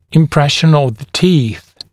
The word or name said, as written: impression of the teeth